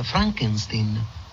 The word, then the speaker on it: Frankenstein: Dr. Frankenstein? – Frankenstein. – Vuol prendermi in giro? – No, si pronuncia Frankenstein.